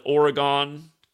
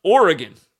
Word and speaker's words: Oregon: They all call it Oregon. Again, I know it's spelled G O N, but it's pronounced just like gun, like a bang bang, shoot 'em up. Oregon.